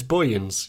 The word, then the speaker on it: Boyens: Is it Bowens or Boyens? – Oh, I've been saying it wrong the whole time. It's Boyens, you're right.